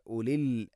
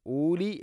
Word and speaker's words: ഉലി: ഫഇതബിറു യാ ഉലിൽ അബ്സാർ, യാ ഉലി മദ് മുൻഫസിൽ, നാലോ അഞ്ചോ ഹർക്കത്തിൻ്റെ ഖദർ നീട്ടാവുന്ന മദ്. അതുപോലെ തന്നെ ഉലി എന്നാണ്, ഊലി എന്ന് ഒരിക്കലും പറയരുത്.